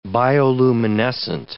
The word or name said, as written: bioluminescent